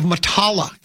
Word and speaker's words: Matala: The Caves of Matala, that's how you say it.